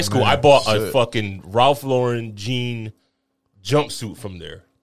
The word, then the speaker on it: Ralph Lauren: Ralph Lauren jean jumpsuit.